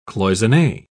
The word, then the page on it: cloisonne: British English